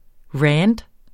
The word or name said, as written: rant